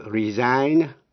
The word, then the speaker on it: resign: Vậy ta biết động từ resign cũng theo lối phát âm như động từ sign, nghĩa là âm G cấm khi là động từ resign. Nhưng khi thành danh từ thì G có phát âm resignation.